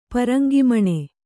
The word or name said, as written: ಪರಂಗಿಮಣೆ